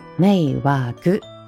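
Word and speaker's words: 迷惑: 不要给别人添麻烦，这个麻烦的发音叫“ 没瓦格”，没瓦格卡开奈的くださいね。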